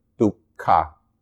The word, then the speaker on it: dukkha: So it's duk or dukkha, the ha.